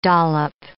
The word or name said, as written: dollop